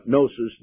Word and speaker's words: gnosis: The word Gnostic comes from the Greek word gnosis, pronounced gnosis, the G is silent.